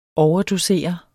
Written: overdosere

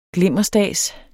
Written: glimmerstads

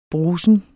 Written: brusen

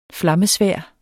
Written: flammesværd

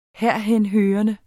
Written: herhenhørende

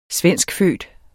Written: svenskfødt

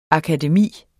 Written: akademi

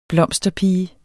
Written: blomsterpige

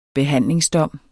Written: behandlingsdom

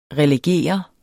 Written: RELEGERE

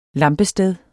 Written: lampested

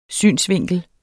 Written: SYNET

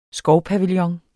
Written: skovpavillon

